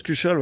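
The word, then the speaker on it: cuchaule: Cuchol, voilà, pour bien prononcer. – Ouais, c'est ça. – Cuchol avec l'accent broyard.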